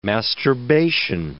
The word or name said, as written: masturbation